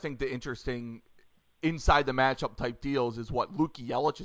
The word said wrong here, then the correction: Yaklich: is that how you pronounce his name, the assistant coach for Michigan that spent time at, where was he, Illinois State? – Yeah, Luke Yaklich.